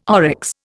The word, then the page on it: oryx: Indian